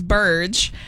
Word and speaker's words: Burge: My last name is Burge. And so people, whenever they ask, how do you spell that? I will just say Purge, but with a B.